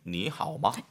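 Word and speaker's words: 니 하오 마 (你好吗): 이렇게 빨리 발음하다 보니까 하오는 정확하게 삼성을 가는데 네네. – 니는요? 삼성이 아닌 것 같아. – 몇 성을 변한다? – 니는 살짝 들어주니까 한 2성 정도? – 맞습니다.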